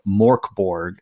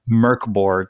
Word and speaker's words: Mörk Borg: It is Merkborg, and it is spelled Morkborg, Morkborg, but it's pronounced, as best I can gather, Merkborg, which is, I believe it is maybe Swedish or Dutch, or it is some Scandinavian language for dark fort.